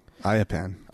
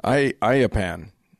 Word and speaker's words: Ayyappan: And it's a shrine that's dedicated to a god called Ayyappan. – That's Ayyappan. – Ayyappan.